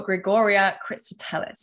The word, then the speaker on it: Grigoria Kristotelis: I am your host, and today I'm sitting here with a very special guest, the beautiful Grigoria Kristotelis.